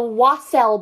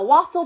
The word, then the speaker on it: wassail: The whatty bowl? – The wassail bowl. I'm pretty sure that's how you pronounce it.